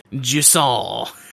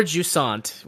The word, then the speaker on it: Jusant: Is a game called Jusson. It's a French word. or Jusant, which is the American bastardized pronunciation that I saw that was like the second pronunciation.